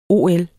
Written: ol

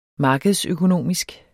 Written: markedsøkonomisk

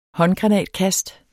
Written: håndgranatkast

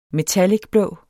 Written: metallic

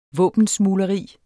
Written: våbensmugleri

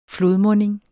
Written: FLODMUNDINGEN

